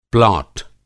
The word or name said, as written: प्लाट